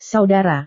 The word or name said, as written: saudara